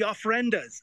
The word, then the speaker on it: ofrendas: Hey, listen, can you tell us a little bit about, and I hope I'm pronouncing this correctly, the ofrendas or altars? – Yeah, that's right, the ofrendas.